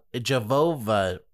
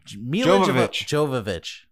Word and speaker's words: Jovovich: Jovovich. – It's not Mila Jehovah's Witnessvich. – I said Joe. – Right, Mila Jovovich. – Jovovich. Mila Jovovich. Okay, yes.